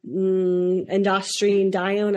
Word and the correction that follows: androstenedione: I don't think I said that right. B, melatonin. C, aldosterone. D, cortisol. E, angiotensin II. – Androstenedione, yeah, which is the answer here.